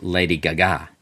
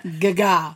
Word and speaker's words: Gaga: First of all, it's pronounced Lady Gaga. – It is not Gaga. I don't know where you got that from. Sounds like something from a Godzilla movie. It's the giant Gaga.